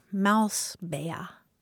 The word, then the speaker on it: Mausbär: Here's an adorable term of endearment from German, Mausbär. – So mouse, mm-hmm. – How do you spell that last part? – B-A with an umlaut R, Mausbär.